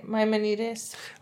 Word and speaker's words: Maimonides: How would you say that? Maimonides? – I'd say Maimonides.